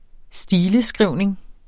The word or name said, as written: stileskrivning